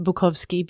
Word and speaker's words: Bukowski: So You Want to Be a Writer by Charles Bukowski. – Oh, I have not. It's hard for me one. to say Bukowski and not Bukowski because Bukowski is the proper pronunciation and I speak fluent Polish, so it bothers me, but it, everybody says Bukowski.